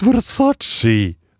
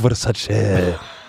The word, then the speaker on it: Versace: Hun bare, it's Versace, not Versace.